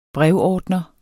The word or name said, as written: brevordner